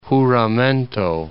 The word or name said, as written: Juramento